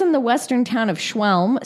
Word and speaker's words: Schwelm: It says police in the western town of Schwelm. That's the town's name. Look, if you want to see how it's spelled, you tell me how to pronounce that. – Schwelm.